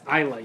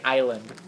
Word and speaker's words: Isla: Isla, you dumbass. – Isla? – The S is silent. – It's like island.